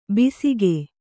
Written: ಬೇಸಿಗೆ

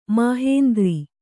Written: ಮಾಹೇಂದ್ರ

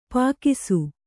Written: ಪೀಕಿಸು

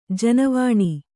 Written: ಜನವಾಣಿ

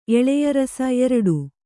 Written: ಎಳೆಯರಸ

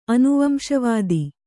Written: ಅನುವಂಶವಾದಿ

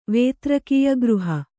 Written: ವೇತ್ರಕೀಯಗೃಹ

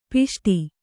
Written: ಪುಷ್ಟ

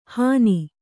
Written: ಹನಿ

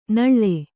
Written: ನಳ್ಳೆ

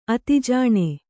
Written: ಆದಿಜಿನ

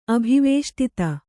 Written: ಅಭಿವೇಷ್ಟಿತ

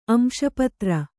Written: ಅಂಶಪತ್ರ

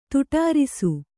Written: ತೊಡರಿಸು

